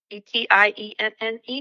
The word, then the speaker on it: Etienne: Etienne, E T I E N N E.